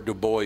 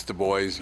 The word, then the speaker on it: DuBois: South It is Du Bois. It's not Dubois. It's Du Bois.